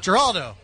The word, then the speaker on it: Geraldo: Geraldo. – Like Geraldo, but with the hard G.